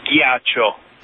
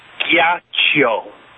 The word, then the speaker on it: Ghiaccio: It's Occhi di Ghiaccio. – It's Ghiaccio? – Ghiaccio. – Ghiaccio. Is it Ghiaccio or Chio? – Ghiaccio. – Ghiaccio.